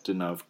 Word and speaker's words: Deneuve: Can you pronounce her name again? – Deneuve, okay.